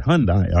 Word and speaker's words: Hyundai: It's not Hyundai, is it? How do you say the car company? – Sphere. Yeah, it's Hyundai. – So I didn't say it right. Hyundai.